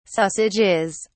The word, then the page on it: sausages: British English